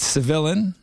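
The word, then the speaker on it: Cevilain: Civillain, that's C-E-V-I-L-A-I-N. I had to reach out to the boys on Instagram to figure out how to pronounce that name, but it's Civillain.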